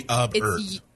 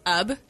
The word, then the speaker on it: Ub: It'll be Ubert. – It's Ub. – Yeah. – E-E-R-T. Mm-hmm. Iwerks. Ubert. That's terrible. I'm sorry, Ub. – Pronounced Ub, Iwerks.